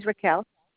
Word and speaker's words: Raquel: My first name is Raquel. – Can you spell it for me, please? – R-A-Q-U-E-L. Last name is Veronica. V-E-R-O-N-I-C-A.